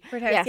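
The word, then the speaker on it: Birdhaus: Am I saying that right? – Birdhouse. – Yeah, I always wonder that when I read it, because it's H-A-U-S. – H-A-U-S, yeah. – That's a fancy. – It's German.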